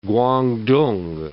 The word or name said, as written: Guangdong